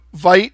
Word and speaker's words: Vite: So Vite is apparently a French word meaning fast, and apparently I now know how to spell it, which is Vit. And apparently the E is silent and a short I.